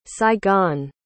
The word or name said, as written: saigon